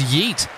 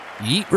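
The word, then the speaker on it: Jeet: And it, look, he's put him in a good spot, but that brings Yeet, the soft J himself, Yeet Revile, to the crease.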